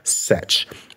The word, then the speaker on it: Cech: And it was written by Erin Sech. The last name is spelled C-E-C-H if you want to look that up.